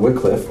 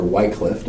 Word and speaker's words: Wycliffe: The first complete Bible, though, much later, John Wycliffe, or Wycliffe, depending on how you like to pronounce that, he gave us the first complete Bible in English in 1382.